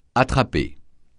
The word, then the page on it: attraper: francais